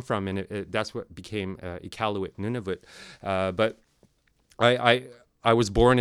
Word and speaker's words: Iqaluit: Iqaluit, that's the proper way to say it.